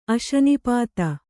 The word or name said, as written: ಅಶನಿಪಾತ